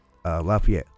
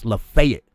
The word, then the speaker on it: Lafayette: But I went down to Tennessee when I was young, and there's a town spelled the same way. Looks just like Lafayette. But you say Lafayette, these people get angry at you. Like, it's not Lafayette. It's Lafayette.